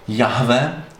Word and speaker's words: Jehova: takže to A-O-A se doplnilo do toho Jehova, takže Jahova, akorát tím, že svědkové Jehovovi jsou Američani, tak to A vyslovuje jako E, takže proto Jehova, jo?